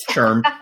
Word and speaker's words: SHRM: SHRM. – Somebody, I kept saying it's S-H-R-M, and I was told, no, it's pronounced in a different way.